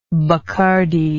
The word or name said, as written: Bacardi